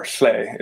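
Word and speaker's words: Lleu: I think it's the same myth, but is a similar scene attached to the Welsh Lu, or Hle is how it's pronounced.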